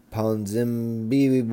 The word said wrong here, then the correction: Ponzinibbio: I don't know how to say this dude's last name. It's Ponzinibbio.